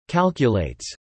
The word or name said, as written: calculates